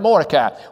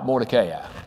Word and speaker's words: Mordecai: Last week, somebody said, "Well, I've always heard it, Mordecai." Well, I listened to it on the Bible being read, and sure enough, they pronounce it Mordecai. But if you look at how it's spelled, I still say I'm right, Mordecai.